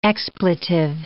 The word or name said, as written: expletive